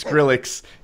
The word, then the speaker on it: Skrillex: Did I say it wrong? – That's Skill Rex. – How do you say it? – Skrillex.